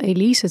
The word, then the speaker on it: EliZe: En toen dacht ik, ja, ik vind het toch wel heel erg fijn om nog Elise te heten gewoon. En met een Z spreek je dan hetzelfde uit.